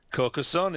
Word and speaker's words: Carcassonne: However, the one that's got most people going, and forgive me if I'm pronouncing this incorrectly, is it Carcassonne? – Carcassonne is exactly right, sir, yes.